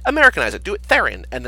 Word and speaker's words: Theron: It's Charlize Theron, not Throne.